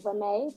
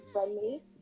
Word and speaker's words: Reme: So when people make lists at my name, they either pronounce it as Reme, but it's pronounced Remy. So like, it's pronounced the French way, as I always tell people, but it's pronounced the Nigerian way.